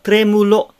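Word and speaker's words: trémulo: el tremulo el tremulo el tremulo tre という発音はよろしいでしょうか。 te と r の間に、母音を入れないように、 tremulo とならないように。これは以前にも申し上げましたが、意識としては tre よりは tre だと思った方がまだ、正しい発音に近くなります。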